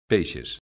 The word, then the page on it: peixes: galega